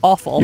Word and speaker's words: offal: O-F-F-A-L. – Yeah, I've heard of it. – Remain, of course, yeah. – But it's pronounced awful.